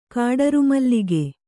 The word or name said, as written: ಕಾಡರುಮಲ್ಲಿಗೆ